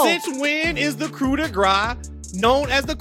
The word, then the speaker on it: coup de grace: The proper pronunciation of it is coup de grace. The reason why it's pronounced coup de grace is because a lot of Americans think that they're pronouncing it the French way by dropping the sound, just like how we don't say the P in coup.